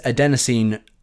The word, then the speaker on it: adenosine: and I always say it wrong. Adenosine. Yes, that's it. I always say like adenosine or something when I just read it off a piece of paper.